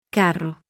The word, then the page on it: rr: double r